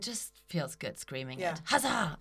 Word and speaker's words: Huzzah: How would you, and our brand, by the way, is called Huzzah. – Huzzah. – How do you spell it? Huzzah. H-U-Z-Z-A-H.